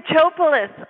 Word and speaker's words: Chopelas: Well, my last name is pronounced Chopalis, just like it's spelled. – Oh, but I was going to say Chopalis.